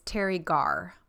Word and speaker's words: Teri Garr: Um, the actress that plays who we will call Phoebe Senior um, is Teri Garr. Mm-hmm. Is that right? Am I saying that right? Yes.